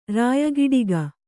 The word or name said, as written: ರಾಯಗಿಡಿಗ